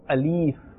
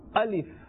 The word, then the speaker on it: Alif: All right, this first letter of the alphabet, some people incorrectly they pronounce it as Alif, so they pull this letter towards the end, Alif, but this is not right. The correct pronunciation is Alif.